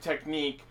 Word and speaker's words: Technic: But yeah, so just to be clear on that, the form that everyone was calling Type Technique, even though it is pronounced Technique because they're Japanese, it is actually spelled Technic. – I'll probably eventually start calling it Technic.